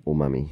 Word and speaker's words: umami: how do you say unami unami umami umami what yeah.